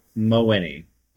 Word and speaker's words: Mawhiney: But Mawhiney.